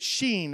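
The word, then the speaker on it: Shin: You pronounce it Sheen. Everyone say Sheen.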